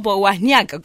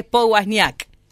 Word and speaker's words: Powasniak: Dije bien, ¿no? Powasniak, como decimos acá en Guale, ¿o no? – Sí. ¿Cómo les va? Un gusto. Sí, tal cual lo pronunciaste, Estefanía Powasniak. – Ahí está, muy bien. Porque viste que otros lo cambian por una vez, dicen Powasniak y demás, pero acá en Guale es Powasniak.